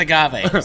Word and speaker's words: agave: No, that's agave. Sorry. – Agave? So funny. – Disclaimer, I'm an idiot. – So funny you bring that up, but it is the most mispronounced word on our menu.